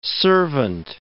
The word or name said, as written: servant